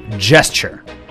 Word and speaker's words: Gesture: and that was a song called Rhythm Jester. Gesture. I think this is how you pronounce this word.